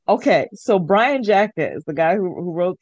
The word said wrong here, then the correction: Jacques: Brian, I think it's Brian Jacques. Yeah. – Jacques, just period.